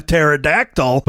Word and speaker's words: pterodactyl: I mean, I thought I saw a pterodactyl, but it turns out it wasn't a pterodactyl. It was a small plane. – Well, and the P is silent.